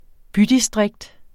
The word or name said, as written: bydistrikt